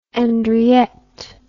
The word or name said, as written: andriette